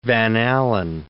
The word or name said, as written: Van Allen